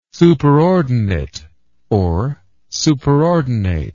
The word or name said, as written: superordinate